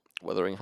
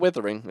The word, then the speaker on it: Wuthering: W-U-T-H. – That's what I'm talking about. Fuck that. – Wuthering Heights. I don't know what the word wuthering means. – What? Is it pronounced wah? – Yeah, it's not withering. It's wuthering.